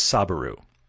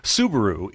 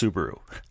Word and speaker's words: Subaru: But Don asked why I pronounce Subaru as Sabaru. – I'm glad you're clarifying. – That's not what I'm doing. Subaru is a vehicle manufacturer. I am aware that it is pronounced Subaru.